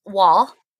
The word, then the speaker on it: Waugh: Evelyn Waugh eh, came and incognito to interview one of the managers of the cemetery and the manager only kind of knew him as Mr. Wall. He like pronounced it wrong.